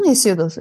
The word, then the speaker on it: Hesiodos: Hı hı, Hesiodos evet.